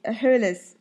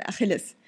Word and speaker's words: Achille: Well, in German, you pronounce it Achilles. Anyway, So it's actually here pronounced Achille in the French pronunciation. – Okay. – Achille, not Achilles. So it's Achille.